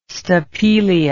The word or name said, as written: stapelia